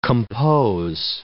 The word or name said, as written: compose